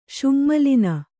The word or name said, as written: ಶುಙ್ಮಲಿನೆ